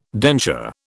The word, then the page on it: denture: British English